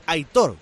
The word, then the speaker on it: Aitor: A ver quién se atreve a decirle a Suancar que se dice Aitor, con acento en la O, que me están sangrando los oídos, pero claro, como es el jefe, estáis calladitos.